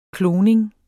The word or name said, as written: kloning